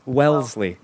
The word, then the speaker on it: Wellesley: Wellesley.